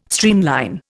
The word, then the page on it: streamline: Indian